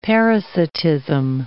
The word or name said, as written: parasitism